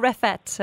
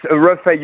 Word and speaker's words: Reffet: Is it Reffet or Reffet? I'm going to get it right. – It's Reffet. You don't pronounce the T.